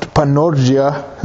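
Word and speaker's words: panorgia (πανουργία): In the original language, it's pronounced panorgia. That's how you'd say it.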